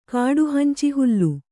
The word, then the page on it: ಕಾಡುಹಂಚಿಹುಲ್ಲು: kāḍu hanci hullu